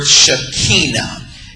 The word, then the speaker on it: Shekinah: is the word Shekinah, S-H-E-K-I-N-A-H, Shekinah. We have often pronounced, I remember growing up hearing this word pronounced Shekinah. It is the word, it is a Hebrew word that means literally the presence.